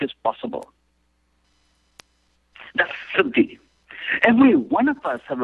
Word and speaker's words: Siddhi: Siddhi, S-I-D-D-H-I, Siddhi.